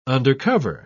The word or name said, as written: undercover